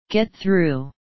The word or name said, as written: get through